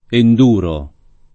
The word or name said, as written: enduro